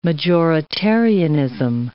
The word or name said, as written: majoritarianism